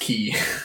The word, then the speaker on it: Chi: It's the Greek letter Chi. – Right. Pronounced key.